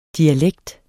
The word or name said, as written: DIALEKTENS